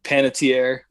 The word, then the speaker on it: Panettiere: Panettiere was.